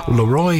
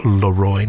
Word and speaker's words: Leroy: Leroy Anderson, and he does insist that it is pronounced Leroy, not Leroy. Even though I've always said Leroy Anderson when I've referred to him as a performer, but no, it's apparently Leroy Anderson.